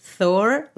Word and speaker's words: Thor: Pues mira, hoy vamos a hacer un episodio un poquito de estos que nos empiezan a gustar, de qué esperamos de Thor, lo he dicho bien, ¿eh? Thor, Love and Thunder.